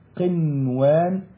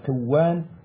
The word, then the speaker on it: قنوان: أمال هنا ما دخلت النون على الواو وعلى الياء قنوان دنيا صنوان. طيب هنا الكلمات ديت دونا عن بقية الكلمات القرآنية والأحكام دي لوحدها. فاش أقول ديا ولا قوان ولا صوان، لازم تنطق هكذا بالإظهار، دنيا وقنوان وصنوان.